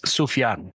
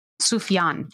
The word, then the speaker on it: Sufjan: So, uh, for just for the sake of pronounce, uh, so you can know the pronunciation, it's Sufjan. Sufjan, thank you.